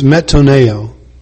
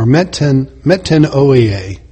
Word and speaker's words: metanoia: The primary word used or translated repentance in the New Testament, the Greek word, is metanoia, or metanoia, whatever.